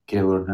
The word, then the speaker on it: Gaylord: How do you spell it? – It's just like as it sounds. G A Y L O R D.